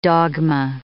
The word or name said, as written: dogma